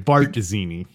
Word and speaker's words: Barzini: We asked Peter Carucci, our Latin scholar from New York City, how do you pronounce Z's in Italian? I kept saying, Barzini. – Oh, right. Bartzini.